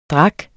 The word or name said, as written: drik